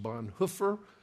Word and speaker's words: Bonhoeffer: I learned last week that, strictly speaking, in the German language, it should be pronounced Bonhoeffer.